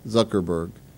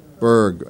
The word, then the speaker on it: Zuckerberg: How you say his name? Zuckerberg. You know, you work really hard for the company, for the Zuckerberg burgers. Burger or Berg? Berg.